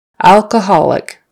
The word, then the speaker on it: alcoholic: It would be more like a short U for that first O. Alcoholic. Alcoholic. Alcoholic with the stress on that next to the last syllable.